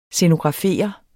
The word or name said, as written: scenografere